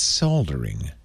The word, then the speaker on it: soldering: By the way, I say it wrong, I know. Don't write to me, all our British fans. It's Leo, it's soldering. – No, not in the United States. – Soldering. – Sorry. – Solder. – Sorry. – We probably sound stupid saying solder. There's an L in there. Don't you see it?